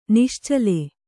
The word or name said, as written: ನಿಶ್ಚಲ